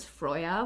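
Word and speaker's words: Freyja: So first and foremost, the medieval Norse pronunciation for her name is Freya, which I sometimes... – Freya? – Is Freya, yes.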